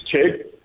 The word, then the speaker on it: Chegg: So I love your pronunciation, Simon. That is a South Africanism if there ever was one. we do pronounce the J as a T, but it's Chegg.